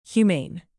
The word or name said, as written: humane